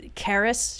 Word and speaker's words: Charis: It's got like a CH at the beginning, but it's Charis.